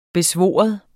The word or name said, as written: BESVOR